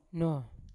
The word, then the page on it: no: Yawa